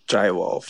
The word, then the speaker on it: Jai Wolf: Is it Jay or Jai Wolf? – I think it's Jai Wolf. – Jai Wolf. Jai Wolf or featuring, Jay. Yeah, work. J-A-I Wolf featuring Rabel.